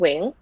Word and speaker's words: Nguyen: So my last name is spelled Nguyen. Mm-hmm. But in Vietnamese, we pronounce it Wing.